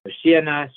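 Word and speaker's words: Hoshiana (הושיעה נא): Hoshiana, stress on the second last, the last syllable, and Hatzlichana.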